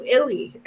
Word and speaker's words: Illy: Is it Illy? – That's right. Illy coffee.